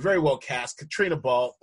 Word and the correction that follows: Balfe: I think I'm messing with it. Yeah, it's Katrina. It's spelled in a very traditionally Irish way, I guess. Katrina Balfe, the lead.